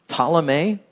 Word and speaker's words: Ptolemy: Then there was Ptolemy, spelled with a P. The P is silent.